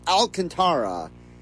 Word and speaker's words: Alcantara: We're getting to the point now where even though Baseball Reference, MLB's website, the Phillies, they all say that the name is pronounced Alcantara. There are people who still are not pronouncing it that way.